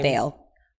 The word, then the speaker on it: Viall: And then now to piggyback off that, when Kristen went on Nick Vial, however you pronounce his name. – Vail.